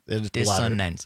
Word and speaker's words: dissonance: Dissonance. You still dissidents. Said, it still sounded dissidents. Like you said dissidents the second time. – Well, I'm sorry for a bad like pronunciation. – I'm just saying yeah. for those listening, dissonance. It might sound like the way Todd's tongue is hitting his mouth makes it sound like a D, but he is saying dissonance with an R.